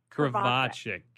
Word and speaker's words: Krivachek: Krivachek.